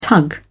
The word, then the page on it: tug: British English